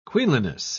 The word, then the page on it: queenliness: British English